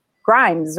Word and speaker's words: Grimes: So today we are talking with Dr. Grimes, right? I don't want to say it wrong. – It's no, like, no, you said it perfectly.